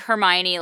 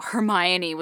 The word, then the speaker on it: Hermione: Yeah, I totally remember like when I learned, because I was really precocious and obnoxious 9-year-old, when I learned that, you know, Hermione was the correct way to pronounce it and everybody else was calling her Hermione. I was like, no, she's Hermione.